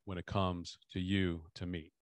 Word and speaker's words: Timnit: So we always call her Timnit because that's the way that her name is spelled, T-I-M-N-I-T. And so I just always say Timnit, Timnit, Timnit, but it's technically Tamit.